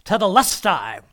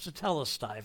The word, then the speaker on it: tetelestai: And that's, and by the way, that's how it's pronounced. I was listening to one guy, and he was tetelestai. No, it's tetelestai. If you're going to use the Greek, at least pronounce it correctly.